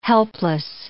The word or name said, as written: helpless